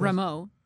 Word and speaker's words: Rameau: Is it Rameau? Is that how you pronounce it? – Rameau.